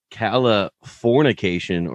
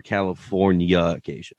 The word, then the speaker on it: Californication: Just for clarification, is it pronounced Californication or Californication? – Californication is how I've always said it. – Because it's supposed to be like fornication.